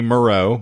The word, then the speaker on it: Moreau: It was by the guy that did The Island of Dr. Monroe. – Richard Stanley. – Yes. He disappeared off the map because that movie was... – Well, that would be Moreau, but I thought I said it right.